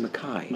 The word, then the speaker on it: Mackay: It turns out he pronounces his last name Mackay.